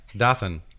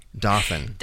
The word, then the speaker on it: Dothan: Most people I talk to this who don't like, know pronounce it like Gotham. – Yeah, Dothan. – Dothan. – Dothan, yeah. How's things in Dothan? – And I just don't even now correct them anymore. – Now it's Dothan.